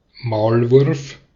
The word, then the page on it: mole: /ˈməʊl/